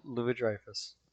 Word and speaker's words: Louis-Dreyfus: Julia Louis-Dreyfus.